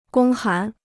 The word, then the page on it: 公函: gōng hán